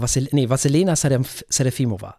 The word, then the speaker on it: Vasilena Serafimova: Und die Dame heißt Vasilena Serafimova. Also Vasilena Serafimova, so.